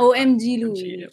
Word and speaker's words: OMGLuie: Ganun ba pronunciation niya? – Yeah, OMG Louie.